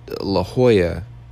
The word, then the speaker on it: La Jolla: Or I want to call it La Jolla, but didn't nobody holler back. So I'm definitely just going to pronounce it the way it should be pronounced. It's spelt like Jala. But La Jolla, 19 people in a row that did not say anything.